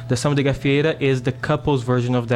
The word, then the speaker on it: Samba de Gafieira: To help you again with the pronunciation, I'm going to say it in Portuguese, it's Samba de Gafieira. But you can say Samba de Gafieira. That's if that helps kind of anglicize it a bit.